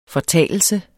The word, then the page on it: fortalelse: [ fʌˈtæˀləlsə ]